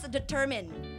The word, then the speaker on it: determine: Yeah, so this word is pronounced determine. Okay, it's not determine.